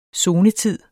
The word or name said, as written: zonetid